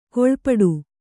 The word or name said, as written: ಕೀಳ್ಪಡು